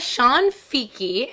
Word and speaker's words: Cianfichi: So, the Italian way to pronounce my name, C-I in Italian, is a ch sound, like C-H. It's actually Shanfiki. My whole life, my mom has always pronounced it Shanfiki just because it seems easier for people to say.